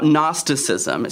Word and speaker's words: Gnosticism: Now, we're going to be talking right now about Gnosticism. It starts with a G, but the G is silent, and the group of people are called Gnostics.